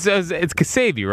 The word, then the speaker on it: Ksebe: Yeah, it's Kasabe, right? – Yes. – So why are you saying K-S-E-B-E? – That's how you spell it.